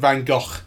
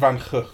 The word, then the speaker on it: van Gogh: As his brother pronounces it. Everyone in England just goes, Vincent van Gogh. Okay. But that's wrong. – Right. – Vincent van Gogh.